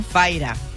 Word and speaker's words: Fyra: Se llama Fira con Y, ¿cómo se pronuncia, esto? – Faira. – Faira. – Faira. F-Y-R-A.